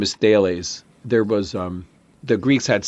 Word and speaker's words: Thales: His name is Thales of Miletus, and it's spelled like Thales, T-H-A-L-E-S, but it's pronounced Thales.